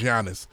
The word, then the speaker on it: Giannis: It's Euro because Giannis Antetokounmpo's name is Giannis. So, and it's spelled with a G.